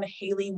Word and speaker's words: Hailey: So I am on TikTok and Instagram at I am Haley Wolf. And my name is spelled H-A-I-L-E-Y because everyone spells that wrong.